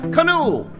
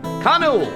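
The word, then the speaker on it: Kanū: Perhaps you should try bent paddle's newest flagship beer, Canoe. It's spelled K-A-N-U, with a line above the U. Canoe, bent paddle beer.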